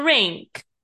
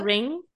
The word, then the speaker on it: rink: It's not a court, it's a rink. Is that how you say it? A rink? A Yeah. – ring? – No. – Rink.